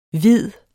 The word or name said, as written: vidt